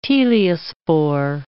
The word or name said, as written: teliospore